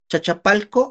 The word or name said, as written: chachapalko